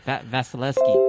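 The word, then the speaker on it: Vasilevskiy: Vasilevsky.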